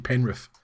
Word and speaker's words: Penrith: People who pronounce the TH in Penrith when knowing clearly that's not how it's pronounced.